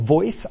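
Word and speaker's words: Voyc: Yes, it's pronounced VOICE, standing for Voice of Your Customer.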